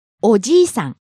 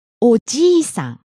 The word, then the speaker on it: おじいさん: And let's listen to the difference in pronunciation one more time. Long vowel? – おじいさん。 – See? The longer it is, the older the person.